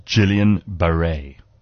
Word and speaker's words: Guillain-Barré: Apparently, it's pronounced Guillain-Barré.